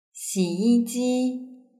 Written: xiyiji

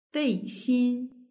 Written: beixin

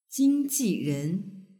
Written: jingjiren

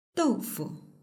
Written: doufu

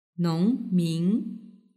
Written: nongmin

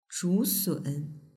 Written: zhusun

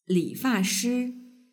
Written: lifashi

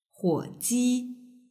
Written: huoji

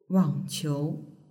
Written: wangqiu

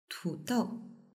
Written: tudou

